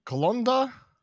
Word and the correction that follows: Klonoa: Col- no, Colonia? – Klonoa. – Klonoa?